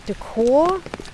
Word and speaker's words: de Caux: Also man kann das eigentlich sehr genau datieren, dass das Einstellungsdatum von Salomon de Caux oder de Caux, also gibt es verschiedene Aussprachen, aber eigentlich wird er meistens so französisch ausgesprochen.